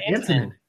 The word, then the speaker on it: Antonin: Antonin. Antonin Scalia. He's the... – Oh, I didn't know his first name. I said anti-NRA. – Oh, yeah. I heard you say that. I'm just saying I pronounced his name wrong, and I'm correcting myself.